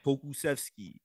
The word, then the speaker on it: Pokusevski: Pokusevski.